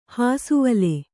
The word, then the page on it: ಹಾಸುವಲೆ: hāsuvale